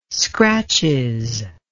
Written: scratches

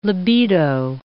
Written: libido

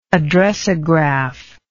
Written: addressograph

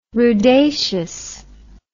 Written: rudaceous

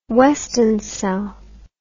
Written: weston cell

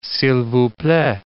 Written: s'il vous plait

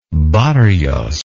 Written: barrios

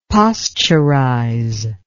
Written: posturize

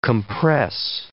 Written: compress

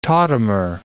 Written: tautomer

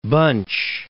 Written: Bunche

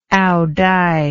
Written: aodai

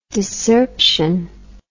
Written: discerption

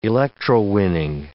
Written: electrowinning

